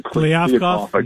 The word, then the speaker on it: Kliavkoff: guy, you know, voting against I can't even pronounce his name, Kliavkoff.